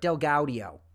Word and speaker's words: Del Gaudio: I don't think that's how you pronounce it. It is. I double checked before this. – Derek Del Guardio? – Derek Del Gaudio.